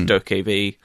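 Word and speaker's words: Dokev: I think it's pronounced Dokevi. It's Dokevi. It just looks mad.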